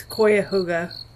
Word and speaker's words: Cuyahoga: It's Cuyahoga.